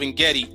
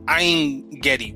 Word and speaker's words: Ein Gedi: So the Hermit of Engedi, Engedi is actually, in science, it is actually the, it is a Latin, or I should say an anglicized pronunciation of the Hebrew word Ein Gedi, which is two words.